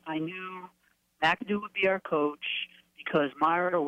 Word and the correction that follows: Mara: was not – is it Myra or – Mara. Mara. – M-A-R-A.